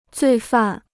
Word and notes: 罪犯: zuì fàn

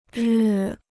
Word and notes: 日: rì

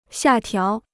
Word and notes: 下调: xià diào

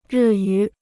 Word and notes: 日语: rì yǔ

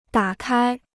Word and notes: 打开: dǎ kāi

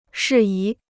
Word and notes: 事宜: shì yí

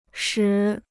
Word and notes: 使: shǐ

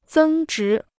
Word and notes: 增值: zēng zhí

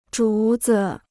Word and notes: 竹子: zhú zi

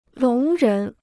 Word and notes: 聋人: lóng rén

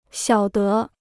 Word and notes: 晓得: xiǎo de

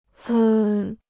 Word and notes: 兹: zī

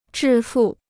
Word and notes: 致富: zhì fù

